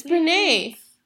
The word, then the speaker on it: Brené: It's Brené.